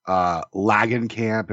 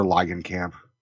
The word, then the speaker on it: Langenkamp: Everyone calls her Langenkamp, and you know, it's Heather Langenkamp.